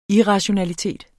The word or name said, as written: irrationalitet